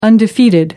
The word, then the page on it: undefeated: American English